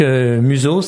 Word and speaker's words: Muzoh: Et cette société donc a créé la marque Museau. Ça s'écrit M-U-Z-O-H.